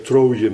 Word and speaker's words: Troje: Wij zeggen Troje met een J, maar die J kun je ook als S uitspreken, hoor. Zoals de Fransen doen.